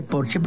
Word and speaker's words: Porsche: o decir que tengo un Porsche en lugar de decir tengo un Porsche, porque en alemán Porsche se dice Porsche porque todas las es al final se pronuncian como Marlene Dietrich, por ejemplo, que todo el mundo dice Marlene, pero es Marlene.